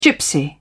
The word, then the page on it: gypsy: British English